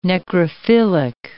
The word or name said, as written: necrophilic